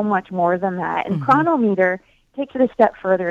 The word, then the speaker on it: Cronometer: It's called Chronometer. Is that how you say it? – Yes, and it's spelled C-R-O-N-O-M-E-T-E-R. It's not spelled quite as it sounds, but I think the app is a great tool.